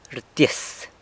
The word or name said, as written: rtyis